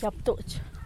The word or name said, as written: gyabtuch